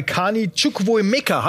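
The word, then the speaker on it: Carney Chukwuemeka: Also da könnte dieses Geld angelegt werden, vielleicht auch bei Kani Chukwuemeka, habe ich ihn gut ausgesprochen. – Wunderbar hast du den ausgesprochen.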